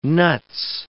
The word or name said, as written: nuts